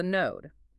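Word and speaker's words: knode: It is a localized, self-sustaining vortex of energy called the node, spelled with a K.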